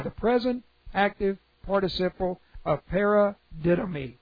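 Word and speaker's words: paradidomi (παραδίδωμι): Para, P-A-R-A, para is a prepositional prefix to the root word didomi. And this is P-A-R-A, that's the preposition prefix, D-I-D-O-M-I, long O there, M-I, paradidomi.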